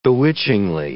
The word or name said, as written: bewitchingly